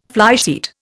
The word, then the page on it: flysheet: Indian